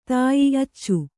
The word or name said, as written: ಧಾಯ್ಯೆ